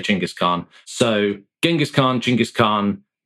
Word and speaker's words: Chinggis Khan: I think if you wonder, you get Chinggis Khan is how most scholars say it properly, but I'm a pragmatist in this regard, rather than strictly politically sensitive and correct.